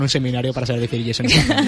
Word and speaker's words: Statham: Jason Statham.